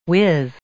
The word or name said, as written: whiz